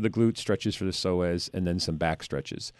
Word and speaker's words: psoas: It's spelled P-S-O-A-S, psoas. – Not like Suez Canal.